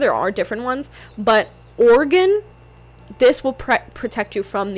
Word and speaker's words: Orgone: Okay, so type in Organite energy pyramid. Energy pyramid. I just want to make sure I'm giving you guys the right pyramid. Or Oregon? Or is it Organite? Oh, Oregon. Oregon. So it's spelled O-R-G-O-N-E.